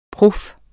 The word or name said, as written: prof